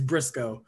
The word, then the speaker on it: Briscoe: Yes, it is Briscoe. Like County Junior.